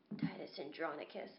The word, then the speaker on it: Titus Andronicus: So in my analysis, because this was the first time I had heard of this band, Titus Andronicus. Am I saying that right? – Mm-hmm.